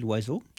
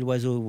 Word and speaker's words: Loiseau: From Émilie Loiseau. – Yeah. – So it's not spelled like a bird because there's a Z in the middle and there's no apostrophe, but that sounds like it.